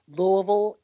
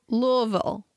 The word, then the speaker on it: Louisville: Louisville, L-V-L is how you pronounce it. – Louisville. Louisville. – Yes. – Yes. – You got it. You're on it. L-V-L. – Okay. – Skip all the vowels.